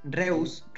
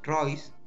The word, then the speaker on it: Reus: Pero vamos directamente al FIFA 17, que salió a mediados del 2016, y que en la portada aparecía Marco Reus, Reus, Royce, se pronuncia,